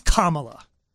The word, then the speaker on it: Kamala: By the way, it's Kamala. I'm not endorsing one candidate over the other, but this girl has been in politics a long time. This woman, dude, say punctuation. What's that? – Comma. – Comma. Say la. – Yeah. – La. – Okay, that's your name.